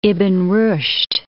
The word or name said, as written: Ibn-Rushd